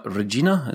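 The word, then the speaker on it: Reggina: We signed him from Regina. Is that how you pronounce it? – Yeah. Right.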